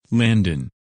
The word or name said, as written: Landon